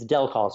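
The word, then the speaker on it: Del Col: It's Del Call. So Del like the computer Del and call like telephone call.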